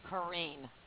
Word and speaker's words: Corrine: Okay, my name is actually pronounced Corinne. – Corinne. – Okay. – Is it spelled C-O-R-E-E-N, or how is it spelled? – Yeah, C-O-R-R-I-N-E.